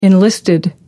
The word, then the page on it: enlisted: American English